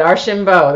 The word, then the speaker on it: Archambeau: Did I say that right? Archambeau? – You sure did. Archambeau. That's perfect.